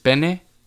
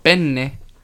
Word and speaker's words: penne: But make sure you pronounce it with the two N's. Because me and Felipe took Italian, and if you pronounce penne with just penne, you're asking for dick. – Yeah. – Because penne means dick. But penne means pasta.